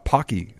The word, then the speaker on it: Pocky: Also, they have miraculously they have Pocky.